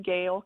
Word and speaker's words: Gayle: It's Sharon Gale Counseling. Gale is spelled G-A-Y-L-E.